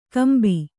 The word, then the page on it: ಕೊಂಬೆ: kannada; kombe